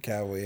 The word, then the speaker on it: Cavill: They got Henry Cavill, is that how you say his name? – Cavill, yeah.